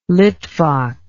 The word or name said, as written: Litvak